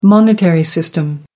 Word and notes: monetary system: American English

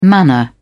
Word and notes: manna: British English

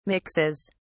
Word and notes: mixes: British English

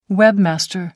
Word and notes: webmaster: American English

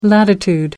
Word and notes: latitude: American English

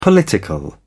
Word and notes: political: British English

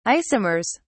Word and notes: isomers: British English

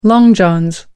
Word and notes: long johns: American English

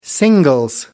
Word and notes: singles: British English

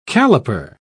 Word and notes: calliper: British English

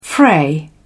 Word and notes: fray: British English

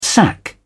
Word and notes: sac: British English

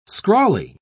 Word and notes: scrawly: British English

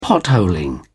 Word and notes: potholing: British English

